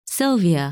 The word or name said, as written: Sylvia